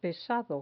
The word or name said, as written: pesado